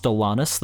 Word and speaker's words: Alanis: But I did watch the Alanis Morissette performance, which, by the way, I do believe it's pronounced Alanis. That's how they pronounced it back when she was on You Can't Do That on Television.